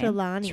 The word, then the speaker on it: Trelawney: Trelawney.